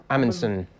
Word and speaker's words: Amundsen: My particular favourite is from Amundsen Brewery. Have I said that right? – Amundsen Brewery. Yeah. They're from Oslo, Norway.